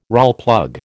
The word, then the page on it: Rawlplug: American English